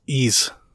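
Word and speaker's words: Ys: Romaine says it's pronounced ease. – Oh, too bad. I say Wise.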